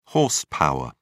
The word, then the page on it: horse power: British English